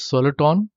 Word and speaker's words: soliton: Hence, soliton rhymes with electron.